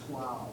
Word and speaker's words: Slough: In England, it's called Slough. – It's Slough. Right.